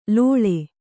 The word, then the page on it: ಲೋಳೆ: lōḷe